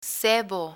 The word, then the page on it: sebo: spanish